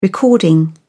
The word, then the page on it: recording: British English